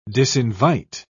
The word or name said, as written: disinvite